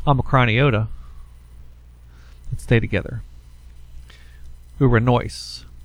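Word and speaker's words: uranoise (οὐρανοῖς): The next word is uranoise. The noise, the accent is on the diphthong omicron iota in the final syllable, and it's uranoise.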